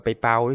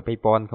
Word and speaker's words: PayPal: Nhờ có bạn một bạn nhắc mà Lát mới biết đọc là PayPal đó, trước giờ Lát cứ đọc là PayPal không à.